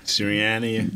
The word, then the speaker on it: Sirianni: Did you guys see the shirt that, how do you pronounce the coach's name? Sirianni? – Sirianni.